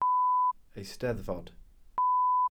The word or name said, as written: eisteddfod